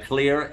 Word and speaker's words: Xlear: And Clear, X-L-E-A-R. You always got to spell that after you say it so you know it's Clear with an X.